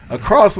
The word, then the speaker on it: across: Across, we always put that T at the end.